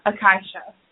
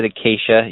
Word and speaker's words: acacia: And it's pronounced Akasha. – Akasha? – Well, I okay. – Play it. – So we were kind of, we had to meet in the middle there. I said acacia, you said akai.